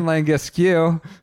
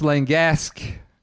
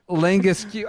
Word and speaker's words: Langasque: Someone who I've originally called Romain Langasque. I'm told it's Langasque.